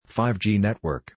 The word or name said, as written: 5G Network